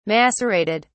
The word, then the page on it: macerated: British English